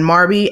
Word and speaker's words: Marbie: And Queen Marby is spelled, well, there's Queen and then Marby, M-A-R-B-I-E, right?